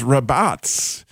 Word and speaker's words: rabatz: And that word is rabatz. And so you can tell that it ends with that Zadik. You can hear that Z sound at the end of rabatz.